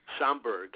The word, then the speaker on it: Schaumburg: Schaumburg. I hope I pronounced that right. – Schaumburg, Illinois.